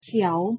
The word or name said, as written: เขียว